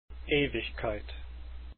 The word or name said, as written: Ewigkeit